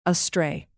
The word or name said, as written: astray